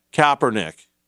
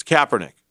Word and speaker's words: Kaepernick: Well, when Colin Kaepernick, or is it Kaepernick or is it Kaepernick? Kaepernick. Yeah, thank you. Thank you. I hear it mispronounced all the time. It's Kaepernick.